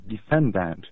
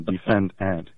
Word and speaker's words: defendant: And one of my favorite examples of that is the word defendant, pronounced as defend, you know, as though you were defending ants, the insects. And that's a very odd pronunciation, defend ant. It sounds unnatural, very unnatural to me. And I used to be a phonologist back in my earlier days. The proper or at least the normal pronunciation is defendant.